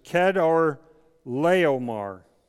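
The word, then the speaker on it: Chedorlaomer: And I thought it was really great the way I was saying it before I went to see how to say it correctly in the Bible dictionary. His name is, I'm going to try this, Kedar Laomar.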